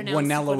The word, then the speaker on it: Guanella: Well, Guanella would be the official pronunciation.